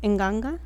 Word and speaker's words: Nganga: So the first one you actually mentioned, I'm sorry, but you mispronounced it. It's actually pronounced Nganga.